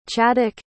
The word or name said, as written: Chaddick